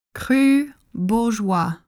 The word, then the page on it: Cru Bourgeois: French